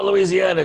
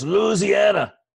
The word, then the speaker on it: Louisiana: So when I played in Louisiana college, I played baseball and basketball. – You said it wrong. It's not Louisiana. It's Louisiana.